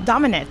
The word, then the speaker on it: Domenech: Domenech.